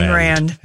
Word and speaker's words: Ayn Rand: Ayn Rand. – Yeah. – A-Y-N.